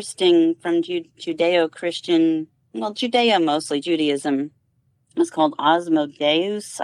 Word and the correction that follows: Asmodeus: I'm not sure if I'm pronouncing it correctly, or Osmodeus. It's Asmodeus.